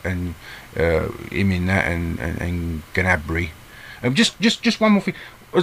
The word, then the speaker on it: Gnabry: The commentators call him Gnabry.